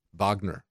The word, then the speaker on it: Wagner: Yeah, Wagner. Wagner. Maybe we should pronounce it that way, because this is Goddamrung for the Bill of Rights, folks, for our freedoms.